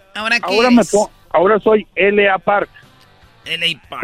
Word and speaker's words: L.A. Park: se pronuncia como la parca. LA Park.